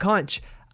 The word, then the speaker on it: conch: I was talking about how these people were stranded and they ate a bunch of, as I said, conch, because that's how it's spelled. But thank you to Tim in South Florida who was able to right my ship. It's called conch. But it is spelled conch.